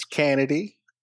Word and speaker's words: Cannady: It's Kennedy. It's spelled C-A-N-N-A-D-Y. That was it. N-N-A-D-Y.